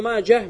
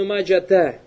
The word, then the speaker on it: Маджа: Что касается Ибн Маджа, Маджа это имя человека. То есть и обязательно надо произносить эту га. Хоть ты останавливаешься или же соединяешь другим словом. Фаля юкалю раваху ибну маджата. Это неправильно, то есть там арбуты там нет.